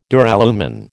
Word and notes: Duralumin: American English

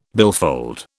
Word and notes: billfold: British English

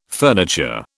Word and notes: furniture: British English

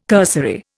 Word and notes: cursory: Indian

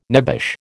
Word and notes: nebbish: American English

